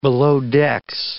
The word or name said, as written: belowdecks